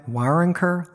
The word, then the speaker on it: Waronker: And from what I understand, Lenny Waronker, am I saying his name right? Waronker? Lenny Waronker. – Yeah.